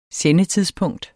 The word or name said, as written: sendetidspunkt